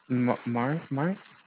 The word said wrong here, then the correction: myrrh: M-Y-R-R-H. I apologize. But that's the other plant. That's the other, I told you I'd mispronounced that plant's name. – Yeah, it's myrrh, I think.